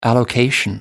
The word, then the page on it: allocation: British English